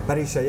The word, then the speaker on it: parrilla: But then the, maybe the second thing I saw was just the way you'd laid it out, just like, um, is it called a parrilla? Parisha, yeah. Parisha. So I've, I've, I've, Parrilla in Spain, Parisha, back to the pronunciation yeah, Argentina.